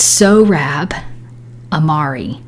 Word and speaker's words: Sohrab Ahmari: I try to look to see how to pronounce it, and it's Sohrab Ahmari.